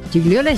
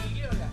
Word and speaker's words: Gigliola: Gigliola es Cinquetti, ¿no? Gigliola. Sí, Gigliola o Gigliola. Yo la conozco como Gigliola. Sí. Se escribe Gigliola.